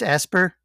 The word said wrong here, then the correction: Aspyr: that's doing the trilogy. – Yeah. Aspyr. – Aspyr. I've been saying it wrong.